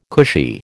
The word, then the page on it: cushy: American English